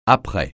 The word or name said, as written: après